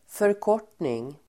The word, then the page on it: förkortning: förk'år_t:ning